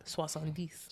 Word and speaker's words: soixante-dix: Soixante-dix.